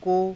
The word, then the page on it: ko: Hindi